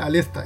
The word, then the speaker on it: Aleste: Se escribe Aleste, pero claro, se pronuncia Alesta, es raro.